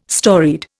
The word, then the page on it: storied: Indian